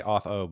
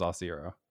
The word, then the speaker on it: Auth0: Yeah, it's Auth0. Lots of people say Auth0, but it is Auth0.